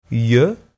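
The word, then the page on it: iue: Provençal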